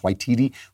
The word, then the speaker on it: Waititi: One of the people who won an Oscar was Taika Waititi, I think it's pronounced Waititi, who made that Jojo Dancer, Jojo Rabbit, which was a comedy about the Nazis.